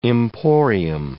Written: emporium

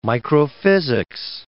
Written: microphysics

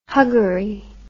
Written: huggery

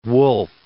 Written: Woolf

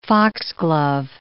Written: foxglove

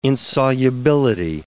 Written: insolubility

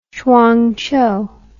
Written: chuangchow

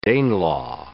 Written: Danelaw

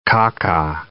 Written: caca

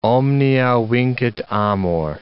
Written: omnia vincit amor

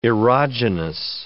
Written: erogenous

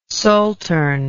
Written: saltern

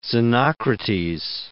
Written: Xenocrates

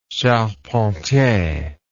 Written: charpentier

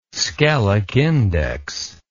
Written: skelic index

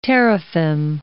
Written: teraphim

